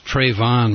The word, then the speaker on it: Trayvon: That's Trayvon Martin, to my embarrassment. I kept mispronouncing the name.